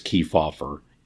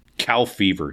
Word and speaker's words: Kefauver: He would call Estes Kefauver intellectually dishonest and ignorant of the history of this country. Truman deliberately mispronounced his name. Cal Fever, he called him.